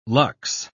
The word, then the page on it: luxe: British English